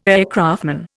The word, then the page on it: aircraftman: Indian